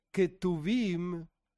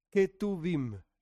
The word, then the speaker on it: Ketuvim: Se le suele decir Ketuvim, porque la forma correcta de pronunciarlo es como si fuese una K. Ketuvim, escritos.